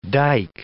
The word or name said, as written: dike